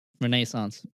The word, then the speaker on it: Renaissance: These next two are both Hoffa's killers, they believe that he might be buried underneath the 73-story Renaissance Center in downtown Detroit, if I'm saying that right. – Renaissance. – Yeah.